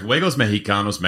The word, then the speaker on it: Juegos Mexicanos: It's Juegos Mexicanos, right? – Correct. Juegos Mexicanos, Mexican Games, is the name of the brand.